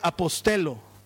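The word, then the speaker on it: apostelo (ἀποστέλλω): Esta palabra envió es una palabra griega que se pronuncia apostelo.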